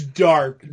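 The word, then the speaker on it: Darhk: The A story is centered around Damian Daharic. Is that how you pronounce that? – It's Dark.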